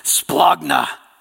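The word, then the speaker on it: splagna (σπλάγχνα): It's splagna, okay? You got to say it like that. You got to hold your hand. Splagna, okay?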